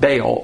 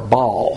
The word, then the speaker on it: Baal: Jezebel was a fanatic follower of the god Baal, or Baal, however you want to pronounce it. Spelled B-A-A-L.